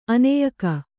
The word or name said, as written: ಅನ್ಯಕ